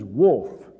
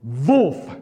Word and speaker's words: Wolf: The English pronunciation is Wolf. But the German was Wolf, Mrs. Wolf.